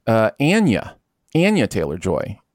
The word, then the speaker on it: Anya Taylor-Joy: I just found out within the last week, I think, that her name is actually pronounced Anya Taylor-Joy, and I never mm-hmm.